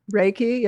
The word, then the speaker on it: Reiki: That Reiki, is that how you say it? Reiki? – Yeah. Reiki, yep.